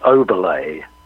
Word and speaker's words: Oberle: Yeah, it's Oberle. It's pronounced, there's a French acute accent on the last E. – Okay. – Which makes it an E. So instead of Oberle, it's Oberle. Yeah.